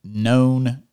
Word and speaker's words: Knwn: You're the pastor of Known Church, but it's spelled K-N-W-N. Did I say it right? – That's right. That's okay.